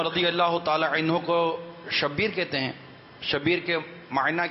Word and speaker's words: شبیر: قول تو شبیر یعنی بر وزن حسین